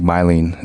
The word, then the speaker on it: myelin: And your white matter, or myelin, I think myelin is the proper pronunciation.